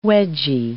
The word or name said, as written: wedgy